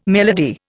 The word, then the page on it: melody: Indian